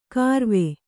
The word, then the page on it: ಕಾರ್ವೆ: Kannada